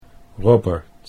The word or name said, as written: Robert